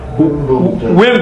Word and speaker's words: Wimbledon: I'm sure there were plenty of goyim who went to Wilmington. How do you pronounce it? – Wimbledon.